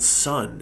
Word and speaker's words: Sunn: It's a duo called Sun, and it's spelled SUNN, and it's called drone music.